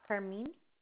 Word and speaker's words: Karmin: This is Carmine speaking. How may I assist you? – I'm sorry, what'd you say your name was? – Yes, my name is Carmine. – Carmen? – Yes. – Is C-A-R-M-E-N? – K-A-R-M-I-N. – K-A-R-M-I-N.